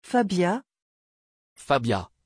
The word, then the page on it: fabia: French